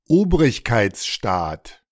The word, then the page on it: Obrigkeitsstaat: German